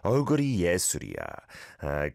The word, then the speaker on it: 얼굴이 예술이야: Oguri yesuriya. Great pronunciation.